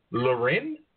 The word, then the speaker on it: Lorin: Yes, it's Lorin. But so many people say Lauren, and it's like – stupid, because now I'm starting to pronounce my name wrong. I'm like, hi, I'm Rachel Lorin. No, I'm not. Oh, crap. So, yeah. but on the record, it is Rachel Lorin, like Sophia Lorin.